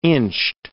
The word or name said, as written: inched